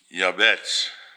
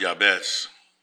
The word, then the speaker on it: Jabez: We need to be like Yabetz in the Bible. The name looks like Jabez to you in the book of Chronicles, but it's pronounced Yabetz.